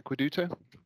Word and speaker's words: AQUEDUTO: I know you're also a founding director of AQUEDUTO. Have I said that right? – That's right. Yeah, AQUEDUTO.